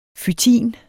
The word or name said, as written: fytin